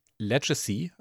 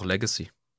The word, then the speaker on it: Legacy: Oh, wenn ich deine Aussprache korrigieren soll, dann äh, sag doch Legacy. Legacy, richtig. Ich habe Legacy gesagt, ne? Das ist Blödsinn. Legacy.